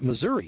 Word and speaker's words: Missouri: And when we see M-I-S-S-O-U-R-I, that's Missouri. But, you know, you come out here to Missouri, and you pronounce that Missouri, and they look at you, and they get kind of offended, and they say, that's Missouri. Missouri. Well, you know where I'm from. If we wanted to pronounce that Missouri, we'd spell it M-I-S-S-O-U-R-A. Missouri.